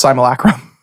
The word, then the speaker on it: simulacrum: So it's the word is supposed to be pronounced simulacrum. – I thought simulacrum. – That's how I've incorrectly said it for years. But yeah. I discovered that it's simulacrum.